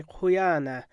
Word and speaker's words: Huyana: For example, Koniag Aleutic speakers say Huyana, with an emphasis on the first A, but if you live on the Kenai Peninsula, you emphasize the second A.